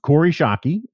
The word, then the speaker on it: Kori Schake: Corey Shockey, that is the correct pronunciation, is it not? – Yes, it is.